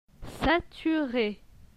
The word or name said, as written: saturé